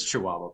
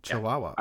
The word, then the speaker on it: Chihuahua: I butchered that name so much. – Chihuahua. – Yeah. – That's Chihuahua, buddy. – Oh, is literally? It Chihuahua? – Chihuahua.